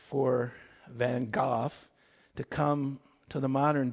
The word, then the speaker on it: Van Gogh: Those of you know Vincent van Gogh, actually Van Gogh is the proper pronunciation, had a pretty difficult life.